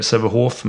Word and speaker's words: Sävehof: Och hon och hennes familj säger Sävehof med F, säger att det ska uttalas så. Stefan Albrektsson, som har varit i klubben längre än någon annan levande person, säger att det ska uttalas med F.